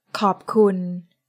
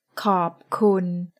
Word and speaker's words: ขอบคุณ: In Thai, thank you is ขอบคุณ. ขอบคุณ. Let's break it down by syllable. ขอบคุณ.